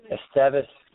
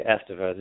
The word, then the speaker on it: Esteves: Yeah, there's debates on that. There's people who say it's Esteves, but I personally say Esteves.